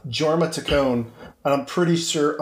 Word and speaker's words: Jorma: I'm pretty sure it's like Jorma. – I think it's something. I think it's Tack One. Just kidding. No. – it's yogging. It's like a soft J.